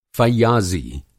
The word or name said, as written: फ़य्याज़ी